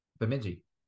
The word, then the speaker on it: Bemidji: Bemidji? – Yeah. – What is it? – Yeah. Bemidji.